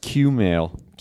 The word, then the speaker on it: Kumail: Kumail and I did a couple shows. We did shows in Portland and then Seattle. – I think it's pronounced Q-mail.